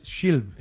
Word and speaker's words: Schilb: Si fuese checo, la pronunciación sería totalmente distinta, pero no, es Schilb.